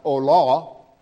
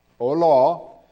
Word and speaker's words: olah: Look on your I've anglicized the Hebrew word for you. It's pronounced olah, olah, and it means to ascend.